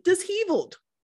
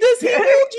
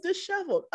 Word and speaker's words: disheveled: So I was pronouncing it disheveled. – Oh, Lord. Ma'am. No. – I was like, yeah, I was so disheveled. He was like, you were so what? – Excuse me? – I'm like, disheveled, you know, just messed up, like, you know, out of sorts. He was like, babe, that's disheveled. – Right. Oh, Lord. I was like, stop. Are you serious? He was like, I can see why you would pronounce it that way, but it is disheveled.